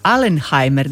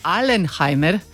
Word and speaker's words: Alenheimer: Ali bit će tu i zanimljiva predgrupa, odnosno predizvođač, otvara ga Alenheimer, dakle ne Alzheimer, ali zanimljivo je odabrao ime Alenheimer. – Alenheimer, da, tako je.